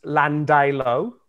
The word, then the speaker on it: Llandilo: So in Sydney, there is a suburb called, and it's pronounced Llandilo.